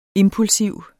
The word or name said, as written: impulsiv